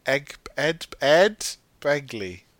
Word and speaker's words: Ed Begley: Oh no, he phones Egg Begley Jr., doesn't he? can I say it right? I said Egg Begley. Ed Begley Jr. Yes.